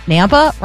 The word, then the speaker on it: Nampa: Nampa rhymes with Tampa.